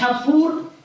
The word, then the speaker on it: کپور: مرحوم همایی در حاشیه کتاب التفهیم میگوید تلفظ اصلیش کپور و با لفظ کفاره عربی یک ریشه دارد.